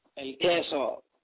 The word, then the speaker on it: El Queso: It's called El Quizo. I'm sorry. Pronounce it right for me. – El Queso. – Sir? – El Queso, El Queso. E-L-Q. – El Queso? Okay.